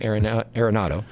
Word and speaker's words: Arenado: Nolan Arenado, I say that wrong all the time, his last name, Arenado.